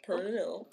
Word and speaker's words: pernil: I say it like that, though, because I think it sounds funny. But Pernil.